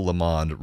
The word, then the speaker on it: Lamond: But please pronounce my name such that Bill Lamond rhymes with Phil the Pond."